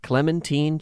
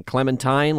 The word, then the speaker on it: Clementine: We are going to talk about Winston and Clementine Churchill. And I'll tell you right now, if you've been saying Clementine like I was, you're saying it wrong. It was Clementine.